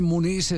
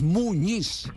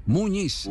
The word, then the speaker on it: Muñiz: Claro, porque en la planilla aparece Muniz y ahí es donde uno encuentra que, sobre todo los internacionales cuando transmiten, no pronuncian como corresponde. Es Muñiz. Muñiz.